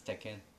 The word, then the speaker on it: Tekken: She pronounced it correctly. But nobody pronounces it correctly. It's one of those things where people, you know, like ketchup. It's people say Tekken. See, she says it like in it was Japanese.